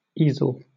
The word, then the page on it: easel: British English